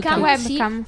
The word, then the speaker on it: webcam: webcam, l'ho detto bene? No, webcam. – Sì, okay. Sì, si dice così.